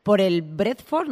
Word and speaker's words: Brentford: Porque, bueno, te retiraste del fútbol hace muy poquito, concretamente una temporada, echando así cuentas rápidas, tras tu paso por el Brentford, no sé si lo digo bien.